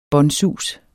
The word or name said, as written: båndsus